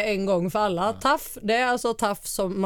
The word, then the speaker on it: TAUFF: Det är ju T-A-U-F-F som taff.